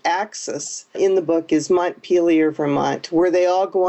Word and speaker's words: Montpelier: For one reason, most people don't know how to pronounce it. Let me just set the record straight and say it's Montpelier, the capital of Vermont, and that is one of the three cities where this book takes place.